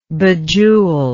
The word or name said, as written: bejewel